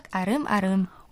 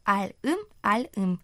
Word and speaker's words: 알음알음: Если имя Арым пишется как А Рым, то слово арым-арым пишется как аль-им, аль-им, а читается оно через р, то есть арым-арым, а не аль-им-аль-им, как пишется, потому что риэль из первого слога встретился с гласной буквой «и» во втором слоге.